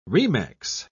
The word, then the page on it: remex: British English